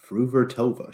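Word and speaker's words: Fruhvirtová: Makes me think Fru- of fruit. Vertova. Linda Fruhvirtova. She has a sister. I forget what her sister's name is, but um, the two young Fruhvirtovas here, we're, we're going to see them a lot, I think, over the next year.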